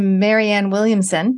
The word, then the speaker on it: Marianne Williamson: So if anyone's got connections to Marianne Williamson, did I say it right? – Yes.